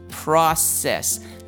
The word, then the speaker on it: process: 那我们的第一个字呢，就是 process。那 process 它是有两个音节，里面的 c 呢，我们应该有听得出来，就是一个 soft c。所以 soft c 我们所以我们的 fine 是发 s 的音，所以那个 s 不是念 k 哦，是念 s，就变成 process。 Process, okay?